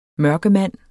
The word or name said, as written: mørkemand